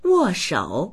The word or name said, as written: wo4shou3